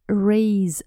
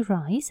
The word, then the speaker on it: raise: Raise one's hat. – Поднять голову. Обращаем внимание на произношение глагольчика «поднимать». Это не rise, это подниматься, а raise.